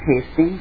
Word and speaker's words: Hastings: My name is Rita Mechanic Hastings, and I work for...